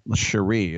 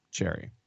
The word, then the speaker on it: Cherry: And I'm not saying Cherie. It's actually spelled like Cherry.